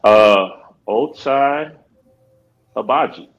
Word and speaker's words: Ochai Agbaji: Ochai Agbaji. Said it right. He made sure he had a pronunciation video for all Cleveland fans to let everyone know Ochai Agbaji from Kansas was the pick in the first round.